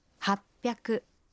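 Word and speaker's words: «Хаппяку» (はっぴゃく): А 800… «Хаппяку».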